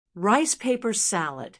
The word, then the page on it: rice paper salad: American English